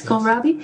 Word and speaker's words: kohlrabi: Well, this year what I'm growing and what I'm really loving at the moment, which probably sounds silly, but it's kohlrabi. I'm not sure if I pronounce it right in English. – Yes, kohlrabi. Yes. It's silly because it's, I don't know anybody else who grows it, but, and I've heard people saying, why are you growing that?